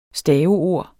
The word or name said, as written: staveord